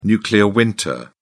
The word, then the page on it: nuclear winter: British English